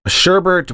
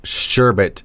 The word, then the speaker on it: sherbet: Sherbert with an additional R in the second syllable and pronounced sherbert is less commonly used, but apparently also counts.